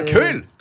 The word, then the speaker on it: Keul: Comment K-E-U. K-E-U-L. Alors, vous qui êtes... – Ah, il y a Keul.